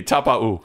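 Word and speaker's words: Taupau: Now, another person who doesn't seem to ever rip his car is Marty Tapu'i. Tapau. Now he's changed. – Tapau. – He's gone back to original pronunciation. – Say it with me. Tapau. – Tapau. Tapau. Okay. Yes. No I at the end.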